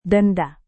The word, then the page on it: denda: Indonesian